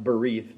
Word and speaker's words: briyth: So the word cutting is the Hebrew word, it's pronounced berith, but it's spelled B-R-I-Y-T-H.